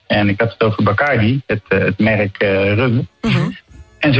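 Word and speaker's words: Bacardí: Nee, want het is dus een Cubaans merk. En er staat inderdaad een accentje op de i als je goed kijkt. En dan spreek je dat dus uit als Bacardi.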